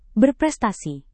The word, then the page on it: berprestasi: indonesian